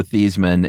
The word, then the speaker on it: Theismann: Yeah, the story goes it was Joe Theismann, and in college he changed it to Theismann to rhyme with Heisman.